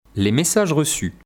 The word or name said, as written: les messages reçus